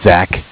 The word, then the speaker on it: Zach: So my name is Zach, spelled Z-A-C-H. – Okay. – I'm calling from Bank of Cardiff. Cardiff spelled C-A-R. D-I-F-F.